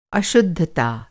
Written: अशुद्धता

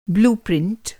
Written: ब्लूप्रिंट

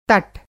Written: तट

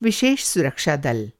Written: विशेष सुरक्षा दल

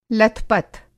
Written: लथपथ

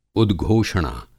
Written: उद्घोषणा

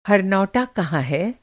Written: हर

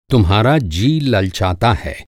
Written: ललचाना

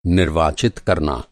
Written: निर्वाचित

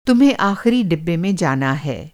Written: डिब्बा

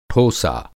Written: ठोसा

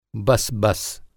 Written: बस

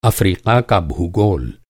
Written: अफ़्रीका

